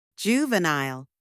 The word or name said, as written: juvenile